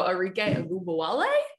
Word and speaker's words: Arike Ogunbowale: Yeah, we welcome in our guest, WNBA scoring champ from a season ago, Arike Ogunbowale. Did I say that right? – Actually, that was really good.